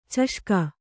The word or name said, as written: ಚಷಕ